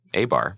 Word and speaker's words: Abar: God Walks Into Abar.